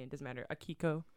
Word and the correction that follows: Akihiko: Is that right? – Akihiko.